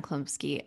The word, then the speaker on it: Chlumsky: and and the the child that they got to play this part anna what how do you say her last Chlumsky. name Chlumsky,